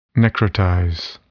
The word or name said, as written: necrotize